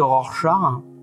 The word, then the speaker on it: Rorschach: Mais Rorschach Rorschach. Et non pas Rocher Suchard. Et eh maintenant, il faut avoir fait de l'allemand. – Ouais. h ça fait. Donc on va parler de Rorschach.